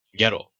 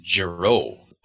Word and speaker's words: Gero: Okay, so in the Japanese version, it says Doctor Gero. Gero. Gero in the English version, they said Giro, like different emphasis, different pronunciation.